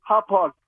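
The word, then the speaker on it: Hauppauge: Out in Hawpog? I'm like, how do you pronounce this? Hawpog, New York? – Yes. Hawpog.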